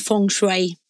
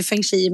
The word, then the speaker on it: feng shui: Men, men framförallt så hur, hur, hur ska vi egentligen uttala begreppet kände jag nu. Alla säger så olika. Ja, men det heter egentligen feng shui. Men jag säger ju feng shui med tanke på min småländska, du vet.